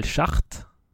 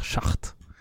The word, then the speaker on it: Schacht: Est-ce que, est que tu es sûr de la prononciation Michael ouais, Hart, Chart, non, je pense que c'est Chart, hein. S-C-H-A-C-H-T, e ça doit faire Chart.